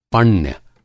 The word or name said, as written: पण्य